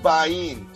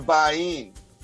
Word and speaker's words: Bajin: You pronounce the last name Bayin, Sascha Bayin.